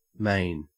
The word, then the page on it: main: Australian English